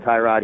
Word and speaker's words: Tyrod: His dad apparently calls him Tyrod. He named him. So we've always called him Tyrod. And, I mean, I've spoken to the man personally many times. I've called him Tyrod.